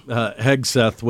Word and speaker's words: Hegseth: And then, of course, the situation with, am I saying his name right, Hegseth? – Yeah. – Hegseth, which I think the focus shifts now, too.